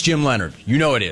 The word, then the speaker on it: Leonhard: There are a lot of names that are not pronounced the way they are spelled, including Jim Leonhard.